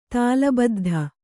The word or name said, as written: ತಾಳಬದ್ಧ